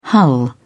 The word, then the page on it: hull: British English